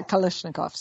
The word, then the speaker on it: Kalashnikovs: Kalashnikovs? – Yeah, Kalashnikovs.